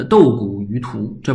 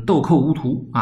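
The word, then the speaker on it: 斗穀於菟: 它是随着时代改变而改变的，所以说它这个不能用今天这个字儿的读音来说当时那个字儿啊，你不能说呃豆谷于涂，这不行，就是豆蔻无涂啊。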